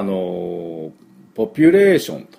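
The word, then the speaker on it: population: 人口はですね、まあ、ポピュレーションと、言いますね。ポピュレーション、はい。で、これ正しい発音でいきたいと思いますが、ポピュレーション、 P の発音がポイントですね。 P が 2つありますからね。 P はスイカの種を飛ばす、プップップップップップップ、こういうことですね。ポピュレーション、ポピュレーションというふうに発音しなきゃいけないですね。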